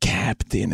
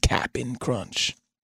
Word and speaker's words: Cap'n Crunch: And by the way, you know it's Cap'n Crunch, right? It's not like Captain. It's Cap'n Crunch. Cap'n Crunch.